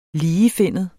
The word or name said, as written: ligefinnet